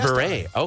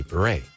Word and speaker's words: Bure: How about Candace Cameron Bure? – Yeah, that was guessed. – Bure. Okay, so that's how you pronounce it.